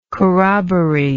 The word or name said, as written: corroboree